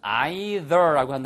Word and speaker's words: either: 나도 뭐뭐 하겠어의 뜻이면 T-O-O, to를 쓰시면 되고요. 못하겠어의 뜻이면 E-I-T-H-E-R라고 쓰시면 되겠습니다. 그래서 I can't believe it either. 영국식 발음은 either라고 한다는 것도 알아두세요. 그렇죠? – You say either, I say either.